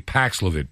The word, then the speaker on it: Paxlovid: I think I'm pronouncing it correctly Paxlovid, P A X L O V I D.